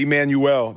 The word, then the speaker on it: Emmanuel: Honestly, high key, that's how I pronounce my name when I meet people. – Yeah. – I say Emmanuel. They think I'm like French.